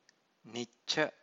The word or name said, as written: nicca